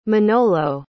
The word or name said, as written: manolo